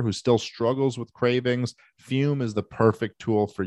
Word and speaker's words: Fum: Of course, Fume is pronounced fume, but it's spelled F-U-M.